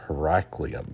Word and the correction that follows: Herculaneum: but I think it's Herculaneum, an ancient city at Mount Vesuvius' foot.